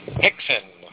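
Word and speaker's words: Rickson: You know, you're supposed to pronounce those with an eighth, right? Hickson, Horry, Holker, Helson, and Royce.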